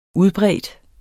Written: udbredt

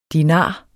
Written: dinar

